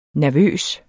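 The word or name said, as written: nervøs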